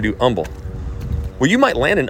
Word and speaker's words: Humble: In fact, I'm saying Humble because that's how the locals pronounce it, but it's humble.